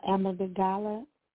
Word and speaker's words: amygdala: The traffic cop, the portion of the brain, it's called the amygdala, and I'll spell it for you. Amygdala is A-M-Y-G-D-A-L-A,